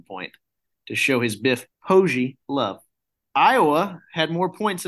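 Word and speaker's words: Poggi: His name is actually pronounced Pogey. Did you guys know this? – No. – It's Biff Pogey, and I learned this because College Game Day was talking about Biff Pogey.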